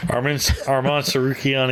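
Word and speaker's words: Arman Tsarukyan: Armand Sarukian against Mateus Gamrot. Yeah, that's how you say it.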